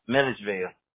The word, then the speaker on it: Milledgeville: and I guess it's only going to be in Milgaville. Like, I think I'm saying that correctly, right? – Milledgeville. – Milledgeville. Okay, sorry.